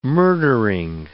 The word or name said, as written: murdering